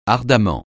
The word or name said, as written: ardemment